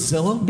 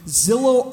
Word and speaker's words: Zillow: Are you guys familiar with Zillow? – Begins with a Z, rhymes with pillow.